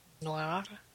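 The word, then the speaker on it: Noire: Apparently we are mispronouncing L.A. Noir. – Le Noir. Sorry, Le Noir. – You're French. Tell us how. – Noir.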